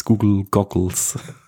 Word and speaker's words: Google Goggles: Also somit bin ich nicht mal traurig, vor allem, weil ich es jeweils nicht richtig aussprechen konnte, dieses Google Goggles.